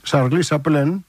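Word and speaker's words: Charlie Chaplin: είναι από τους μοντέρνους καιρούς του Τσάρλι Τσάπλιν, 1936, Σαρλί Σαπλέν, που λένε και οι Γάλλοι, με αυτή την άθλια προφορά.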